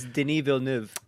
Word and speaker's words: Villeneuve: Denis Villeneuve. All right, well, nope. it's French-Canadian. It's Denis Villeneuve.